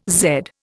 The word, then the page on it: Z: Indian